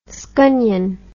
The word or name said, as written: scunnion